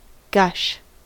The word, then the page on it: gush: American English